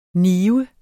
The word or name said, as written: NIV